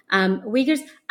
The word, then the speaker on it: Uyghur: Yeah, I'm really interested in your methodology because there's, you know, you occasionally talk about ethnographic research, having interviewed Uyghurs. I'm pronouncing it as Uyghurs. How do you pronounce it? – Uyghur. – Uyghur. Okay.